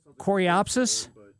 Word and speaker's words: coreopsis: We put in, I'm not going to pronounce it right, a wonderfully flowering perennial coreopsis. Is that right? – Coreopsis? – Coreopsis, yes, sir.